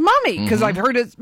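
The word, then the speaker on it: Mumy: He pronounces the name Mummy.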